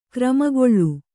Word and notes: ಕ್ರಿಮಿಕುಲ: krimi kula

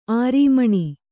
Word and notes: ಆರೀಮಣಿ: arīmaṇi

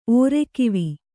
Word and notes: ಓರೆಕಿವಿ: kannada

